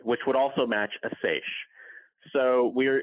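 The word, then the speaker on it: seiche: I say seish. How about you? – Talk to 10 different people and you'll hear 10 different pronunciations of that word. We usually call it seish.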